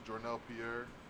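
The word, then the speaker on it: Journell Pierre: You can find me on all streaming platforms, Jernell Pierre. You can follow me on Instagram at not Jernell Pierre. Jernell Pierre is spelled JOURNELLPIERRE.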